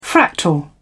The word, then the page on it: fractal: British English